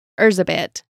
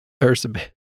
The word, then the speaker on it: Erzsébet: And her name was Erzsébet, but I will be saying Elizabeth. – Erzsébet. Okay. – That's what it was in Hungary.